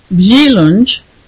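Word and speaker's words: Wieluń: It's actually pronounced Wheelunge, but I'm just going to pronounce it Wheelan because that's how it looks in English, because it's too difficult for me to try to pronounce the Polish.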